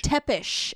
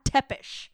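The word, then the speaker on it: Țepeș: Tepish. – Okay. Vlad Tepish.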